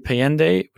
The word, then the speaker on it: Payandeh: And if you want to find me specifically, I'm on Twitter at AJ Payandeh, which is my last name, and that's spelled AJPAYANDEH.